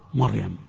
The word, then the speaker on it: مريم: أنا أقول للجميع هذا الكلام، كلمتان يكثر الخطأ فيهما، فرعون ومريم، يخطئ كثير من الناس فيفخم راء فرعون ويرقق راء مريم، يا مريم، ويرقق راء مريم فيقول يا مريم، يا مريم اقنتي لربك. والصواب العكس فيهما، الصواب العكس فيهما.